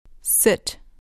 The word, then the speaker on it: sit: Can I sit here? Sit. Sit. Can I sit here? – دقت کنید که تلفظش سیت نیست. باید بگید ست. یه صدای بین ای و ای.